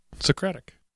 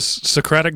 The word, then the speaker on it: Socratic: That's right, Socratic. – Okay, because I thought, you know, Socrates, it would be pronounced different. – It's the Socratic method. Yes, that is the correct way to say that.